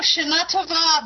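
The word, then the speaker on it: Shana Tova (שנה טובה): Oh, Shana Tova. Very good. You're pronouncing Hebrew like an Israeli, and Shana Tova means for a good year.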